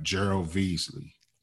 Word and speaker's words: Gerald Veasley: You had a great episode with a gentleman named, I hope I pronounce it right, Gerald Veasley. – Is that how you pronounce it? – Yeah.